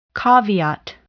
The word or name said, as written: caveat